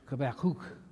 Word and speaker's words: Habakkuk: If you weren't here 2 weeks ago, we began a series on the book of Habakkuk, or as I guess the correct pronunciation is, Habakkuk. But we'll call it Habakkuk so you don't just kind of roll your eyes and wonder what that's all about. But we're studying the book of Habakkuk,